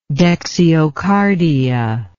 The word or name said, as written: dexiocardia